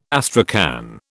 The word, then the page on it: astrakhan: British English